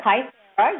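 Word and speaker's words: Kybella: Did I pronounce that right? Kythera? – Kythera, yes, indeed.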